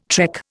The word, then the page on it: trek: Indian